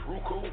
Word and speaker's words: Roku: All right, so also, too, I know you got the situation with Roku. Did I say that right? Is it Roku? – I think it's Roku.